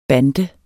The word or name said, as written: bande